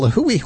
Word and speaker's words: Lihue: Another story coming out of Lihue, Hawaii, which is, and I pronounced it right